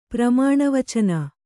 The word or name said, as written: ಪ್ರಮಾಣವಚನ